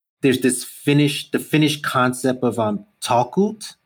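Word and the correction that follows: talkoot: did i say that right Torkut? There you go. That sounds much better than what I just said.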